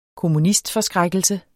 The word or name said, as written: kommunistforskrækkelse